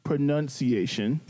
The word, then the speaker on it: pronunciation: Pronunciation. – Pronunciation. – There's no O. It's pronunciation. – Pronunciation.